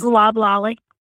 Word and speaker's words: loblolly: There's the loblolly pine, I think. I'm not going to say it correctly, but it's loblolly. – Loblolly. Thank you. She's coming to my rescue.